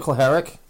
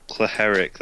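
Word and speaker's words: Cehlárik: Kelleric? – Oh, Peter Klaharik? – Klaharik, that's it.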